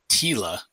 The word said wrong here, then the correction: Tula: Tula, right.